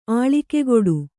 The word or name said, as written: ಆಳಿಕೆಗೊಡು